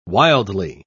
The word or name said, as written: wildly